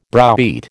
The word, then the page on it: browbeat: American English